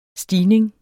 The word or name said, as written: stigning